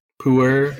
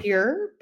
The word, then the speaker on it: Pu-erh: Oh yeah, P-U-E-R-H. But yeah, I got you. – Yeah. That one. – Pure. – Okay. No, puer.